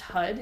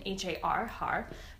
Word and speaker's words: Har: The chant is HUD. It's pronounced HUD. It's written H-A-R, HAR, but it's HUD.